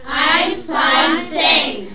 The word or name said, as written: I'm fine thanks